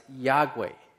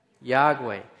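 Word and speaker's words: Iokwe: And this one here, the word that is on it is pronounced Yahweh. Can you all say that? Yahweh.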